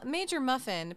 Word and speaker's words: MajorMuff1n: We're moving on to Major Muffwinen. – Major Muffin. – Well, it's Major Muffin, but the I is a one. – Oh, yeah. – So I didn't know if I should pronounce it.